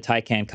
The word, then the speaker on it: Taycan: The Taycan constantly impresses me. I'm probably saying that wrong too. – You're good. – No, you're good. You're, that's right.